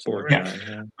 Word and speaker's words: Borgnine: I think he gives an insane performance, especially like as I'll butcher how to pronounce his name, Ernest Borgnine. I think that's Borgnine.